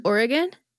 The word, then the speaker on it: Oregon: Are you saying Oregon with three syllables like a non-Oregonian? – Oregon? – Oregon.